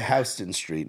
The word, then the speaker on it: Houston Street: And it's right by Houston Street, not Houston. They call it Houston there, which is again odd. It's spelt like Houston, but. I even used to call it Houston Street. My dad was like, "No, it's Houston."